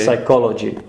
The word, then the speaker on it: psychology: Psychology, also on the second syllable.